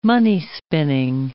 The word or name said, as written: money-spinning